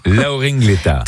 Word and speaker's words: Laoring Leta: Laoring Leta. – Très bien. – Ça s'écrit, non pas comme ça se prononce.